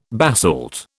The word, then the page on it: basalt: British English